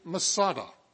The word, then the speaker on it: Masada: We pronounce it Masada.